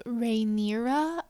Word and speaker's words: Rhaenyra: Correct my own pronunciation of Rhaenyra.